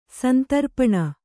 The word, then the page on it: ಸಂತರ್ಪಣ: santarpaṇa